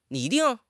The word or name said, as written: 拟定